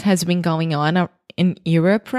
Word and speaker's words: Europe: So imagine you're like your, but with a really redneck, you're Europe. Like it's your turn. Es sua vez. You're up.